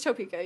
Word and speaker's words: Topeka: Topeka? Is that how that's pronounced? – Is that... – Yeah. I hate it. – It's Topeka, yeah.